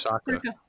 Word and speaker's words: Saka: And Saka, the player he put on, I hope I'm pronouncing his name correctly. – Yes, Saka. That's correct.